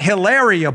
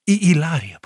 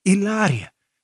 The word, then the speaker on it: Hilaria: It's not Hilaria Baldwin. It's, how do you say it? Ilaria. Paula is actually a native Spanish speaker. Ilaria. Silent H.